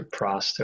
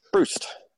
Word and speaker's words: Proust: Absolutely, but yeah, no, I agree with the bulk of what you said there, especially about Proust or Prost, however you pronounce it. – Proust. – There you go.